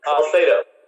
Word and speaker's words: Salcedo: Let me read, if I could, sir, from I guess it's an article today, Town Hall, by Chris Salcedo, if I'm saying that correctly. – Salcedo. – Thank you. Thank you, sir.